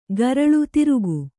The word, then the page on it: ಗರಳು: Kannada